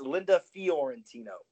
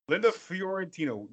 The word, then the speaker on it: Linda Fiorentino: So, yeah, it's Linda Fiorentino. – Linda Fiorentino.